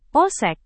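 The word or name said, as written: pon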